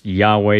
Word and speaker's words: Yahweh: Now, just to remind you, if you may have skipped the spoilers section last week or you didn't listen to last week, yes, YHWH is typically pronounced Yahweh.